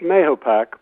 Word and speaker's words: Mahopac: It's Lake Mahopac. – Mahopac.